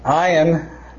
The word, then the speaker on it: ayin: But it's pronounced ayin.